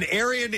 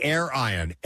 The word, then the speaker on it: Airion: And Arian is not like Arian Nation. It's spelled... Or it might be Air-Ion. Air-Ion. Air-Ion. A-I-R-I-O-N. But it looks like Arian to me.